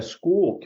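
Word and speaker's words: Skool: Soon we're going to be unveiling a school community. It's S-K-O-O-L, and it's pronounced school, a school learning community.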